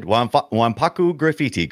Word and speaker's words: Wanpaku Graffiti: Splatterhouse Wanpaku Graffiti. – Pretty good. Pretty good. Wanpaku Graffiti, correct.